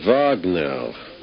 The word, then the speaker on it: Wagner: Yes, lovely lady, but you pronounce it with a V, Wagner.